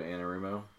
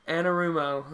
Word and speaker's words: Anarumo: Lou Anarumo. – Anarumo.